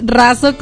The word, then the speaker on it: Razo: Lisette Razo con Z, y bueno, ahí me buscan en Facebook.